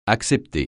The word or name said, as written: accepter